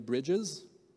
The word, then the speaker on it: Bridges: Et en passant, je vous parlais de l'auteur tantôt, de Jerry Bridges. Il faut prononcer le S à la fin, j'imagine, en anglais, tu prononces tout le S.